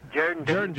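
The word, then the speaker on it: Jourdanton: You got to pronounce it right down here in South Texas. It's Jerdanton.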